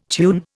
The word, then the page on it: tune: Indian